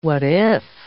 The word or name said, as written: what-if